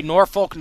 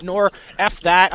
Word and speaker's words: Norfolk: Norfolk, Norfolk, not nor F that.